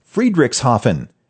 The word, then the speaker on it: Friedrichshafen: It's pronounced Friedrichshafen. There's no sh anywhere in there.